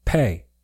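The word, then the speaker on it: pe: So the Hebrew number or letter that corresponds with 80 is the letter pay, which rhymes with pay, as in cha-ching.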